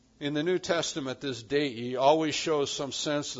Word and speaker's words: dei: Three letters, two syllables, dei.